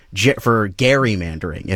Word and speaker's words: gerrymandering: make sure that Seth knows that it's a hard G for gerrymandering, if you will.